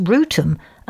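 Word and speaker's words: Wrotham: My favourite, though, not rude, is Trottiscliff, pronounced Trosley, with nearby Rotham, W-R-O-T-H, pronounced Rootum, and Igatham, pronounced Item.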